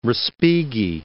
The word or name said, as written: Respighi